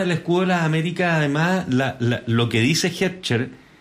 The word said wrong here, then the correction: Hegseth: ¿Lo pronuncié bien? ¿Cuánto es? – Hegseth. – Hegseth. No es Hegseth. – No. – No, Hegseth.